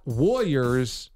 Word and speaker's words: Warriors: Why can't we pronounce Warriors? W-A-R-R-I-O-R-S. It's not Warriors. There's no why. It's Warriors.